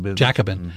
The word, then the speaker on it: Jacobin: Jacobin.